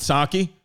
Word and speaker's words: Psaki: Oh, is it Psaki? – It's, yeah, Psaki bomb. – I said Jen Psaki. – That's fine. It's like that other guy with a silent T. You got a lot of silent consonants.